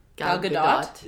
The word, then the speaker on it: Gadot: You pronounce the T at the end. – Gal Gadot.